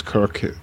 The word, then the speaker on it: Kirk: My name is Kirk Corliss. It's spelled K-I-R-K. Last name is spelled C as in cat, O-R-L-E-S-S.